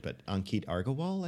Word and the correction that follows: Agarwal: I think. – Yeah, yeah, Agarwal, yeah, yeah.